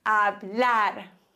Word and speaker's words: hablar: Bei hablar haben wir dann die Regel, dass wenn ein Wort auf Konsonant endet, das ist hier der Fall, Konsonant ist alles außer A, E, I, O, U, wir haben ein R, dann wird die letzte Silbe des Wortes betont. Hablar.